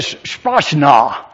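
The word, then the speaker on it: sprachna (σπλάγχνα): I don't know what your particular translation is, but I need to translate the word for you, and I want to pronounce it for you first of all so you can go home and impress your friends. The Greek word is sprachna. Isn't that a wonderful word? And it is the word sprachnos. S-P-L-A-G-C-H-N-O-I-S.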